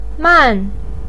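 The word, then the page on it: man4: pinyin; tone 4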